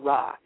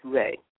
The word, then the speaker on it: Re: There's also Re, or who people commonly call Ra. It's really supposed to be pronounced Re.